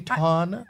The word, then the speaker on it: Tana: Don't you know how to pronounce the name? Tana? – You're right. Tana.